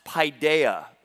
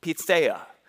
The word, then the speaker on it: paideia: Now, it looks like if you transliterate it, it looks like it would be pronounced paideia or paideia. But I checked with my resident Greek expert, who is Greek himself, Mr. Tom Skoulis, and as he did his research, it's actually pitheia.